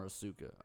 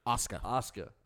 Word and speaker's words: Asuka: I want to keep calling her Asuka. I know that's wrong. – Asuka. – I think it's spelled Asuka, and that's my problem.